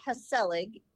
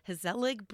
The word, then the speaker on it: Gezellig: And yeah, tell the listeners how it's spelled, so when they see it, it definitely looks different than how it's pronounced. – It sure does. It is spelled G-E-Z-E-L-L-I-G. – Perfect. Okay. Hasselig Brewery.